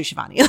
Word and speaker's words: Sheevani: I've had so many people like, I know you say Sheevani, but like, how is it really pronounced? I'm like, it's actually Sheevani. Like, you're saying it right.